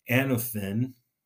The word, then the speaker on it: anothen: And the Greek word here, where we get the phrase born again, is actually anothen, is how you say it in Greek.